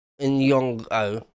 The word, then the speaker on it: Nyong'o: Lupita Nyong'o.